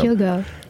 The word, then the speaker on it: Hyogo: Hyogo.